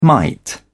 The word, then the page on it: mite: British English